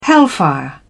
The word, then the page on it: hellfire: British English